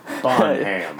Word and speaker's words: Bonham: Bonham.